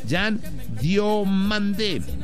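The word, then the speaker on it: Yan Diomande: Jan Diomandé. – ¿Quién? – Ah, no, de hecho se pronuncia Jan Diomandé. – ¿Mandé? – Exacto, Jan Diomandé.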